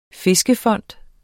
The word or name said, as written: fiskefond